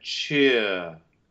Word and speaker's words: cheerful: In North American pronunciation, we would have, probably most people would transcribe, a closed E and then a rhotic schwa. So you have cheer. – Right. – It should open a bit in the main part in the British English if you're deciding to make this very good shift into the British pronunciation. – Uh-huh. – It should open to I, and then the R reduces to schwa. So you get from cheer, you get cheer. – Which will also then sing more easily because the vowels lead into each other better than ear.